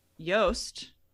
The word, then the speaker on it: Joost: Looking forward to having you hear our interview with Yoast, rhymes with toast, but spelled JOOST, Allard, and Pete Reynolds, who are co-founders of Learn Deep.